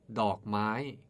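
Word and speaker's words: dok mai (ดอกไม้): The word is dok mai. Say it together with me. Dok mai. Dok mai is a Thai noun which means flower. It is a compound word with two syllables, dok and mai.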